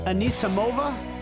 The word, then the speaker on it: Anisimova: Anisimova? Is that how you say her name? – Right.